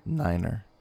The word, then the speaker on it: niner: And every number is just pronounced as the number except for the number 9. – What's 9? – Niner.